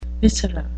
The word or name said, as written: viscera